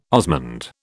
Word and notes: osmund: British English

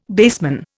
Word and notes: baseman: Indian

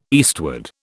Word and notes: eastward: British English

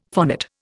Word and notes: phonate: Indian

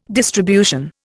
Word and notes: distribution: Indian

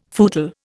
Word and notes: footle: Indian

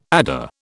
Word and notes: adder: British English

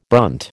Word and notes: brunt: American English

